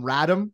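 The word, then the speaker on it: Radim: I was upset that to hear Cuthbert call him Radom, because I thought he was Redeem, and I sent the tweet that was Redeem the Dream, and I'm like, you idiot. I mispronounced his name.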